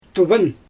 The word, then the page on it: tuvan: Xyzyl